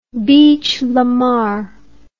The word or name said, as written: bichelamar